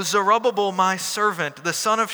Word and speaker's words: Zerubbabel: That you pronounce that Zerubbabel in American English.